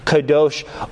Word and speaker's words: kadosh: You would basically spell that K-A-D-O-S-H, K-A-D-O-S-H, kadosh. Or kodesh would be K-O-D-E-S-H. Kodesh, K-O-D-E-S-H.